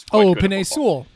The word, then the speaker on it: Penei Sewell: And the tackle who I never understand how to pronounce his name correctly from Oregon, who's Penei Sewell. Yeah.